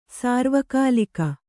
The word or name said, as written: ಸಾರ್ವಕಾಲಿಕ